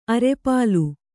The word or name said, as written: ಅರೆಪಾಲು